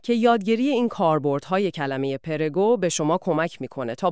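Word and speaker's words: prego: آره، حرف جی تو این کلمه نباید خیلی سفت تلفظ بشه. صدای نرم شبیه به آوای ژ تو زبان انگلیسی کلمه پلژر یا همون ژ خودمون میده. و حرف اوی پایانی هم باید کاملا کشیده تلفظ بشه. پرگو.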